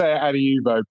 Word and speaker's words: Adiuvo: Is it Adiuvo? – We say Adiuvo. People say it many different ways.